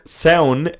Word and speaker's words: Samhain: S-A-M. Oh my God. – And what bloody Supernatural did, Michael, was they took sound, S-A-M-H-A-I-N.